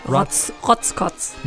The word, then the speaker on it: Rotzkotz: Did I pronounce that right? – Rotzkotz. – Yeah, Rotzkotz. That's how the English, that's how we say it in America.